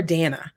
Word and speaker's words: Dana: When driving Postmates, a person will come down and say, me and my wife were debating how you say your name. Is it Dana or Dana? And I'm like, it's Dana.